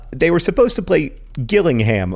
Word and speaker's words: Gillingham: It is pronounced Gillingham, soft G like jam, and that's important because Gillingham, hard G like girl, is a different town in England.